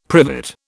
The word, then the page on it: privet: British English